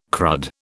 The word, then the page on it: crud: British English